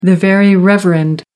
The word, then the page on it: very reverend: American English